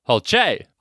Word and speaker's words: Hulce: Is it Tom Hulce? Hulce? H-U-L-C-E. How would you say that? – Hulce. – Hulce. Let's go Hulce.